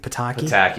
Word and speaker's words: Pataki: Pataki.